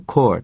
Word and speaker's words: Corps: って言うんですね。で、まあ、Reserve っていうのはですね、この場合予備役の意味でよく使います。軍の予備役です。いわゆるですね、戦争状態になると予備役が招集されるというようなことですが、これジュニアなんですね、学生ですからね。そしてそのリザーブオフィサーズトレーニング、トレーニングなんですね。最後がですね、コアと発音しますが、実際のスペリングは CORPSS ついてますが、まあ発音はしないんですね。Core で、PS のところはまあ発音上無視して発音した方が自然ですね。Core と発音します。これはPeace Corps っていうのを皆さんご存知だと思うんですね。平和部隊。実際には軍隊ではもちろんありません。開発途上国なんかにですね、援助に行く人たちで Peace Corps って言います。このその時の Peace Corps の Core